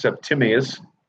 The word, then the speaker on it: Septimius: Well, and forgive me, because that's how his name is spelled. It's actually pronounced Septimius.